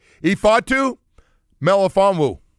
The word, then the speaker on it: Ifeatu Melifonwu: Ifeatu Melifonwu.